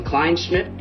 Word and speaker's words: Kleinschmidt: We have Jason Kleinschmidt, did I say that correctly? – You did.